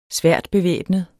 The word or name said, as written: sværtbevæbnet